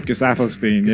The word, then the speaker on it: Gesaffelstein: Gesaffelstein, is that how you say it? – Gesaffelstein, yeah.